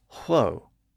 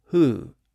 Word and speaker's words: who: That's what happened to the word who, spelled W-H-O. It was originally pronounced hwo, H-W-O.